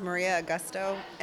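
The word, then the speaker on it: Maria Agosto: My name is Maria Augusto, M-A-R-I-A, last name A-G-O-S-T-O.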